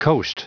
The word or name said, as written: coast